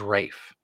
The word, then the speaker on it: Ralph: And even though I believe his name is spelled Ralph, I think they even pronounce it Rafe.